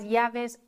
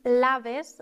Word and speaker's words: llaves: Achtung, wir sagen nicht laves, sondern wir sagen llaves, denn das Doppel-L wird im Spanischen immer ausgesprochen wie ein deutsches J.